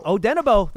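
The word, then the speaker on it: Odenigbo: Yeah, Odenabo. That's exactly right. Yeah. That G in there is silent. That is exactly it.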